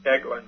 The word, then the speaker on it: Eglin: I believe it's pronounced Eglin.